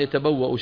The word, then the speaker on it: يتبوأ: يتبوأ شايفين الهمزة مكتوبة كيف على واو كما ذكرنا منذ أيام وبعدها ألف زائدة في الخط تكتب ولا تلفظ